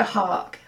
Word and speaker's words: Haack: Yeah, Viktoria Haack. Haack, the Herald Angels sing.